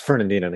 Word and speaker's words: Fernandina: Did I say it right that time? – Yes. – Closer. – Fernandina. – You almost just swallow the A. – It's like there's Fern and there's Dina. So let's ask Fernandina to come over.